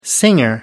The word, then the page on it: singer: American English